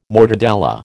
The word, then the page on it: mortadella: American English